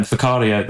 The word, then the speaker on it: Whakaari: Um, maybe again uh, the, the, the eruption in in in Whakaari, um, Fakaria, I think is the way pronounced.